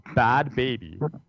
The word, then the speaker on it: Bhad Bhabie: She's going under the name, I think I'm pronouncing this right, because in typical hip-hop fashion, it's spelled incorrectly, Bad Baby. B-H-A-D-B-H-A-B-I-E. – Oh, shit.